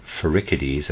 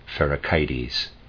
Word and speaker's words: Pherecydes: Chthon is spelled C-H-T-H-O-N. And I would like to say I'm going to pronounce a word Pherecydes, that's how I was pronouncing it before. I've decided I want to pronounce it Pherecydes, and Syros is S-Y-R-O-S.